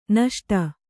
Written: ನಿಸುಟು

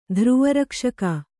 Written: ಧ್ರುವರಕ್ಷಕ

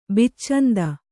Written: ಬಿಚ್ಚಂದ

